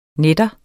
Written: nat

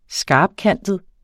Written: skarpkantet

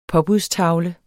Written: påbudstavle

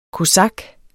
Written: kosak

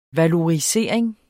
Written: valorisering

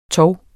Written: TOVENES

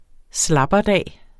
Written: slapperdag

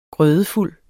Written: grødefuld

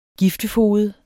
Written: giftefoged

